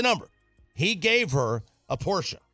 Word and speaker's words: Porsche: Porsche, as my neighbors told me to pronounce it properly. – Mm. – Porsche.